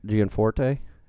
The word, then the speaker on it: Gianforte: I think having a worse week than Donald Trump is Republican candidate for Congress, Greg Gianforte. Am I pronouncing this right? – Sure.